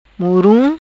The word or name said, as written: Murum